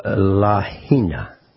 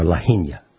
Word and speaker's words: Lahaina: So Lahaina, or Lahaina, it's pronounced several different ways, L-A-H-A-I-N-A, was destroyed.